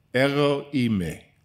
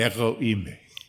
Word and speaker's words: Ego eimi: Ego eimi. Learn that from me. From your wife, I think, taught me how to say that correctly. Gerard's wife can speak Greek. I messed it up and she corrected me. Ego eimi.